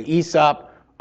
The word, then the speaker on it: Aesop: Some people say Aesop, some people say Aesop, I say Aesop. I think the emphasis is on the E, the A is silent, but there's reason to disagree and it's not a big deal.